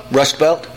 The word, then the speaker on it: Rust Belt: He has charisma with the people in this rest Belt. I pronounce that well? – Rust Belt. – The Rust Belt.